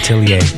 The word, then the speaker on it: atelier: And Flip Out's DJing in Toronto at Atelier. I don't know if I'm pronouncing it right. – Atelier.